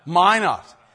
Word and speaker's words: Minot: This is the service where, at the end of the service, Edmund Way, who was a minister from Minot, North Dakota. Is that how you say it? Minot? Minot? Whatever. Minot. Is it really? Thanks. I never knew how to say that in all my life.